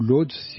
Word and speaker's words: Łódź: Elle est directrice de l'Académie de Łódź en Pologne, ou Lodz si vous préférez la prononciation à la française,